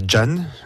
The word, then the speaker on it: Can: Donc V-O-I-E-S, Épicées, qui a été créée par Jan, ça s'écrit C-A-N.